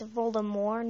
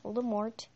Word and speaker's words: Voldemort: Oh, and for those of you who don't know it, it's Voldemort, not Voldemort. The T is silent. It's Voldemort. For those of you who think it's Voldemort, you're wrong.